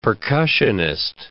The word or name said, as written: percussionist